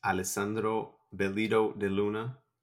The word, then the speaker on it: Alessandro Bellido De Luna: My name is Alessandro Bellido De Luna.